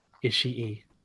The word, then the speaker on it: Ishii: Can I just say, Ishii? – Tiger Mask W. That's my trademark. Come on. – No one ever pronounces the extra E.